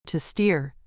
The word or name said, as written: to steer